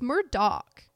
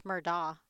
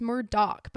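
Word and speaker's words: Murdaugh: I think it's Murdaw. – No, it's Murdoch, but it's spelled Murdaw. Like, that's the weird thing about his name, is it's spelled Alex Murdaw, but it's Alec Murdoch.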